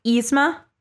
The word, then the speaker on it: Yzma: I keep saying the name wrong in myself. Yzma. Thank you, Alexis.